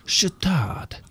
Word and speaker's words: Chatard: Chatard. – Yeah. Chatard. – It's just C-H-A-T-A-R-D.